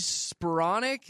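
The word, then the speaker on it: Spiranac: It's that Paige Speronic. Is that her last name? – Oh, the golfer chick. – Is that how you pronounce her last yes.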